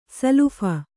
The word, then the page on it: ಸಲುಫ: salupha